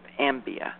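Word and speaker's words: Ambae: The entire population of Ambia, that's how you say it, is being moved from the path of this increasingly active Moreno volcano, also known as Lambenben.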